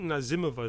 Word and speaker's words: Nazimova: This is the Camille that stars Nazimova. I think that's how you pronounce it. We kept all of us going back and forth. Nazimova, but I think Nazimova is the more Russian pronunciation.